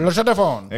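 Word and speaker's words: La Chaux-de-Fonds: Es man pat bail izrunāt tās komandas nosaukumu. Lašodefons vai kaut kas tāds. Es nezinu, nu, tur ir Lašodefons.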